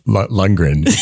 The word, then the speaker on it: Lundgren: You look like Dolph Runglin. – Lundgren, I think. – You look like Golf Lundgren. Am I saying it right? – You're close enough.